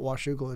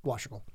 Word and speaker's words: Washougal: I said, "It's not Washougal. It's Washougal. Just one. It's one syllable."